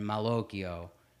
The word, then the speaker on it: malocchio: So the Moloch is the, I guess, Americanized version or the Americanized pronunciation, and Malocchio is the Italian pronunciation.